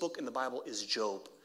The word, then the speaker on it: Job: It's spelled Job. It's not. His name is Job.